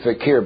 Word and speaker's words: fakir: The pronunciation is fakir, but in my books he's a faker. And he's supposed to get on the platform here and they wave you goodbye.